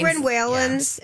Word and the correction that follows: Marlon Wayans: Am I saying his name right now? The Waylon brothers. You know, Marlon Wayans.